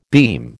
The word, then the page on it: beam: American English